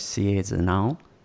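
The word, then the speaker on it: Sierre-Zinal: Now, I may pronounce this wrong, the famous race, Sierre de Nal. – No, that's perfect. Yeah.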